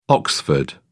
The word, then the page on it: oxford: British English